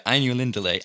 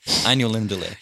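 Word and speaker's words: Ainulindalë: Anu yeah, Ainulindale. Ainulindale. Sorry for those of you who are Tolkien fans out there. I mispronounced it. Ainulindale.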